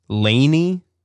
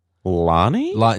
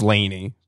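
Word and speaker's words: LANY: And then we got, I think it's pronounced Laney, but you know that band, Jason. Capital L, capital A, capital N, capital Y. Lonnie? no, nah, yeah, I think it's Laney for sure.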